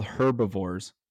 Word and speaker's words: herbivore: They're called herbivores. – I said herbivore. – You said herbivore. Oh, okay. Never mind. Sorry. – Is that really the H is silent in the U.S.